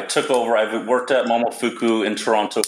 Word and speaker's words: Momofuku: So tell me about when you were at, I would hope I pronounce this right, Momofuku.